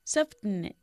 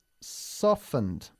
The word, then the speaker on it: softened: Softened. – No, softened. Primero la T de soft no se pronuncia ahí. Softened, ¿ok? – Softened.